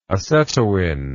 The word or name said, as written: acetoin